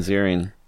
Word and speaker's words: Ziering: Ian Ziering. – Yeah, I just recently learned that's how you say his name.